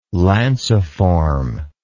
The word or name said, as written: lanciform